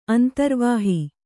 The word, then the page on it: ಅಂತರ್ವಾಹ: antarvāha